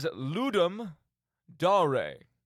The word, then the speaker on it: Ludum Dare: The actual pronunciation, and bear with me here, is Ludum Dare.